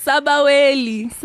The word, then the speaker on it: Sbwl: So this thing, your Sbaweli, what was Sbwl? How do you say it? How do you say it? Because like we need a clip of Mihlali saying it's Sbaweli.